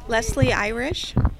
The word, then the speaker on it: Leslie Irish: Leslie Irish. – Okay, Leslie Irish, how do you spell that? – L-E-S-L-I-E, Irish, I-R-I-S-H.